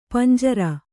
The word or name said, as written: ಪಂಜರ